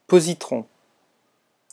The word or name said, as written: positron